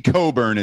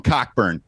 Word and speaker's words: Cockburn: Kofi Coburn is his name. I didn't want to mispronounce that. I usually call him Cockburn. I want to get that right today because that means something totally different that you can get on spring break.